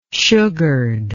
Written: sugared

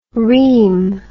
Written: reem

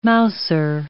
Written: mouser

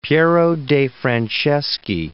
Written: de'Franceschi

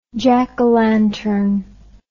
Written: jackalantern

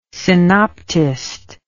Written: synoptist

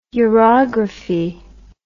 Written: urography

